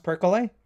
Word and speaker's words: Perkele: This one showed up on that list and it's Perkele. – Mm-hmm. – Did you see this one? – Perkel, that's what I said. The first one. Is this pronounced Perkele? – Which essentially means evil spirit or the devil.